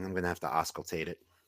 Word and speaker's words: auscultate: Auscultate.